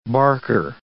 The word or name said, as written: barker